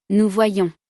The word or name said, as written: nous voyons